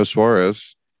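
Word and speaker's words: Suárez: Suarez. – Not a Eugenio Suarez. And you said it right that time, too.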